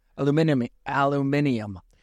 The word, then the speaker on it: aluminium: The British say aluminium. – You're getting to my head with your Brazilian mind tricks. – Aluminium is how they say it, which is the actual element, and Americans say aluminum, but I don't know why.